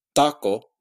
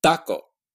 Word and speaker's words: taco: In Spanish doesn't have aspiration, but it also has a dental place of articulation. So it's not pronounced taco. It's pronounced taco with the tongue tip at the teeth.